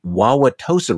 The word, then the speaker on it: Wauwatosa: She, which is in Waukesha County, she shows up at the Merton School Board meeting to complain about the treatment of a Wauwatosa resident by the Wauwatosa School Board.